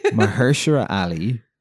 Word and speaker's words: Mahershala Ali: Mahershala Ali is an actor who's in the third season of True Detective.